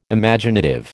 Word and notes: Imaginative: American English

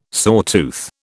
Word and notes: sawtooth: British English

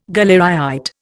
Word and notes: galleryite: Indian

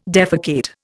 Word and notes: defecate: Indian

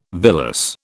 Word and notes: villus: British English

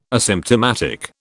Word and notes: asymptomatic: British English

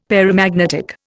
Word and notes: paramagnetic: Indian

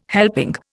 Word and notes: helping: Indian

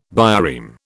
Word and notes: bireme: British English

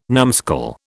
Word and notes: numskull: British English

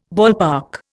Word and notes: ballpark: Indian

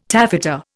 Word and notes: taffeta: Indian

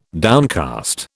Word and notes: downcast: British English

